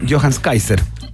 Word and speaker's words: Johannes Kaiser: Tiene que ver con los dichos absolutamente descalibrados, deleznables y destemplados del diputado electo por el Partido Republicano, Johannes Kaiser.